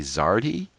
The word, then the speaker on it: Zardhe: And of course, right now I got to ask you, how do you pronounce Z-A-R-D-H-E? Zardy? – I pronounce it Zard.